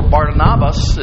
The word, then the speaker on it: Barnabas: Barnabas, or Barnabas as his name is correctly pronounced, is a pivotal figure in the New Testament.